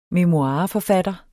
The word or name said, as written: memoireforfatter